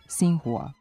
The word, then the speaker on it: Xinhua: U tom odeljku navedeno je da se X iz kineskog pinjina ispred slogovnog I izgovara kao S. Osim za prezime kineskog predsjednika Xi-a, to važi, na primjer, i za ime kineske novinske agencije Xinhua.